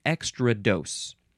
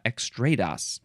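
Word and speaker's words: extrados: Now it looks like, to my American eyes and brain, it looks like extra dose, but there's a few ways you can pronounce this. You can say extra dos, or extra dough, or extradas. Is that the last one? Yeah, extradas.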